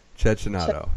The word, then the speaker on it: Cecchinato: Cecchinato.